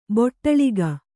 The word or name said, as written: ಬೊಟ್ಟಳಿಗ